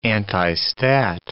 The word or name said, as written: antistat